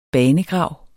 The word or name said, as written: banegrav